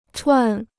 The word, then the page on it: 串: chuàn